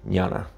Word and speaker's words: jnana: Mis genes salieron extrañas ahí, pero esto es J-N-A-N-A, -N se pronuncia como una ñ, ñana, un poquito más sutil.